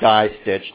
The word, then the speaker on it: distich: And a distich, or a die stitch, depending on how you want to pronounce it,